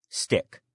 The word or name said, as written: stick